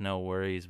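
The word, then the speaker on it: NxWorries: It's supposed to be pronounced No Worries, but it's NX Worries.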